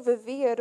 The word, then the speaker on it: vivir: And then I asked her to repeat it, and she said it slower, and I was like, oh, she's saying vivir, but she's pronouncing it with a B.